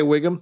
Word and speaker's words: Whigham: Shea Whigham, the great Shea Whigham, yeah.